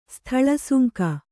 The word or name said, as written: ಸ್ಥಳಸುಂಕ